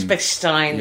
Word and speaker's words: Bechstein: It's Bechstein.